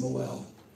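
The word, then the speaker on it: mohel: It's actually pronounced Moel.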